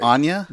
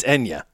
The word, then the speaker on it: Enya: That wasn't Anya? – No. I don't know that's how you say it. Is it Enya? It's Enya. Isn't it? – If you say so.